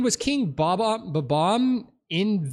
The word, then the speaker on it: Bob-omb: It sounded like he was saying bomb, but with a stutter. Bob-omb.